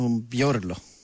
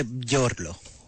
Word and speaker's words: Μπιόρλο: στην ισιογραφία όντως δεν έκλεισε αυτός ο Μπιόρκ, που δεν είναι Μπιόρκ. – Ο Μπιόρλο. – Ο Μπιόρλο. Να βάγισε τον Μπιόρλο στην ΑΕΛ. – Αν μάλλον προφέρεται Μπιόρλο.